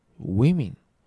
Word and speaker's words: women: 複数形になるとそれぞれ girls、そして women、 women、いって音になるのね。